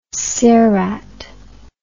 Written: cerat

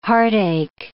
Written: heartache

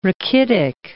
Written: rachitic

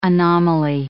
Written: anomaly

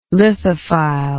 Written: lithophile

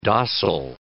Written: dossal

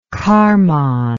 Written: karman

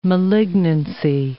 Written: malignancy

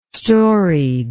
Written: storied

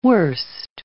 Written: worst

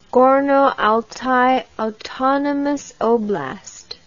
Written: Gorno-Altai Autonomous Oblast

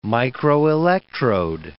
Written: microelectrode

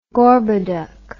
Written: Gorboduc

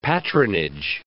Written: patronage

